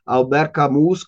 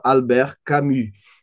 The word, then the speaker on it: Albert Camus: É, curiosamente, depois eu conheci o Horácio Gonçalves, que é argentino, e na Argentina eles ignoram solenemente a maneira certa de pronunciar os nomes estrangeiros nas línguas originais. Então ele fala Albert Camus, Camus, Camus. Enfim, eu não conhecia Horácio Gonçalves, não conhecia o Camus, e só depois é, que eu comecei a estudar francês e percebi que se pronunciava Albert Camus.